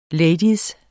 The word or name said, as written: lady